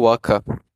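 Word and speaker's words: Wacha: É Michael Waka. Waka. Tipo a música Waka Waka.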